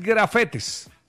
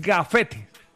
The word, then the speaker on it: gafete: No, porque no es grafetes. – Ah, ok. – Es gafete.